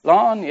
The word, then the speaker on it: Larne: Is that, did I say it right? Larne, yeah.